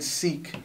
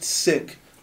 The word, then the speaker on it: Sikh: So, a lot of people say Sikh because in London, like, the English, like, colonized India for, like, 100 years, right? So, okay. They said Sikh, but it's pronounced Sikh.